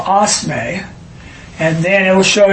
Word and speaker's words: osme: Oh, it's pronounced O-S-M-A-Y. – Osme. Okay. – They spell it, though, O-S-M-E.